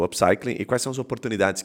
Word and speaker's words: upcycling: que é o tal do upcycling. É assim que pronuncia? Correto? Upcycling, ok.